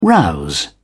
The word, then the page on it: rouse: British English